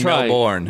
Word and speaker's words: Melbourne: You have to learn to like get rid of the R sound. – You can spell Melbourne. You can spell it M-E-L-B-A-N. Melbourne.